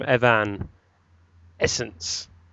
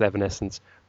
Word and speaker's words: Evanescence: I've had a few suggestions from Evan Essence from Twitter. Or I think it's... Well, maybe it's meant to be pronounced Evanescence.